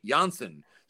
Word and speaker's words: Jansen: And that at that point, he was still preferring the Dutch pronunciation of his last name, which was, of course, Kenley Jansen. So you had Kenley Jansen, the catcher, now Kenley Jansen, the closer.